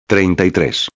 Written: 33

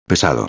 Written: pesado

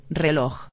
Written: reloj

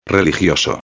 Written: religioso

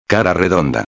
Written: redonda

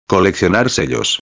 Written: coleccionar sellos

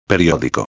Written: periodico

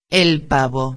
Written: el pavo

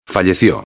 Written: fallecio

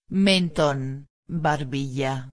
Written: menton barbilla

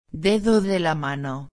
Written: dedo de la mano

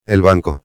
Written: el banco

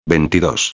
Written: 22

